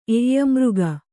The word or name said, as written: ಎಯ್ಯಮೃಗ